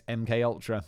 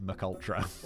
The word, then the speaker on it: MKUltra: I remember in one of my first ever videos on YouTube, I pronounced it McUltra. I don't know why. It's MK, it's not MC. I pronounce it like some sort of McDonald's burger, because I'd never heard of it before. But it's like, you know, it's not McUltra.